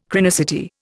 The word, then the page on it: chronicity: Indian